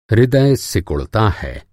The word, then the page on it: हृदय: ہردے